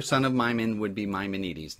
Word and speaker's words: Maimonides: So I'm going to move us along and say, unfortunately or fortunately, the great Jewish sage Moses Ibn Maimon, known by the mispronounced anglicized version of his Hellenized Arabic name Maimonides, or proper Greek, it would be called Maimonides.